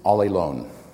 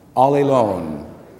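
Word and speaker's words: allelon (ἀλλήλων): The Greek word for one another is allelon. Isn't that hilarious? So, let's say one another in Greek. Everyone, allelon. It's not spelled the same way, but it's a good way to remember. We are not called to be all alone.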